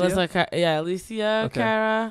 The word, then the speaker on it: Alessia Cara: Is that how you say it? – Yeah. Alessa. Alessia Cara.